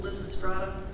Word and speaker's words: Lysistrata: When you were talking about Greek plays, have you ever thought about Ms. McFadden producing Lysistrata? Please correct my pronunciation, but that's right.